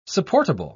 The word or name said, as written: supportable